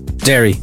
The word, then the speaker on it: Derry: It rhymes with Kerry. Derry.